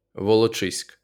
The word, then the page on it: Волочиськ: [woloˈtʃɪsʲk]; Ukrainian